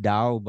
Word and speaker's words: Tao: Yeah, Tao is actually pronounced Dao, but it's fine.